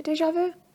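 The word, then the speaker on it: déjà vu: And the only way I can explain it is like a deja vu. However you say that word. – Yeah. – How do you say that? – Isn't it deja vu? – Deja vu. Yeah.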